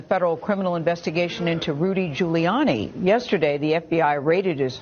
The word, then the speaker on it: Giuliani: Giuliani.